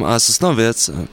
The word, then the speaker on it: Sosnowiec: I'm from Sosnowiec. – Sosnowiec. Sosnowiec. Said it wrong. Okay, yes. I'll just make sure you say it right.